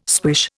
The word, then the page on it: swish: Indian